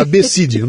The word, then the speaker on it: Abecid: Porque se escreve ABCD, então era Abecid o nome dele, né?